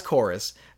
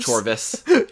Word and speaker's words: Chorus: It was it was Chorus, but it looks like it's spelled Chorvs. – Yeah. – Chorvs. – Chorvis. – Yeah, it's Chorus.